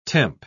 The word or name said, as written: temp